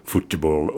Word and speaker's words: futebol: Spelled F-U-T-E-B-O-L. – That's the Brazilian spelling pronounced futebol, and of course it's, you know, being Brazilian, it's probably the proper spelling for football now, I should think.